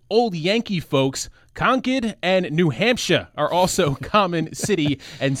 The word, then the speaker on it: Hampshire: Eric Gallagher, a Democrat from Concord, also wants to include an official pronunciation for New Hampshire, making it clear that S-H-I-R-E rhymes with fur and not fire.